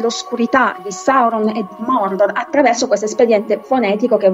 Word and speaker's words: Mordor: Attenzione al detto Mordor, anche Tolkien pronuncia proprio così Mordor con una R molto marcata e,